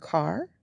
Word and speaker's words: QAR: Our car is named QAR. And it's pronounced car, but it's the Queen Anne's Revenge because that's the name of a ship and she's a boat.